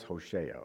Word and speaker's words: Hosea: Even though we don't have an illustration of what God looks like physically, I believe Hosea, I've heard it pronounced a million ways, Hosea. If I say it wrong from here on, just know it's Hosea, okay?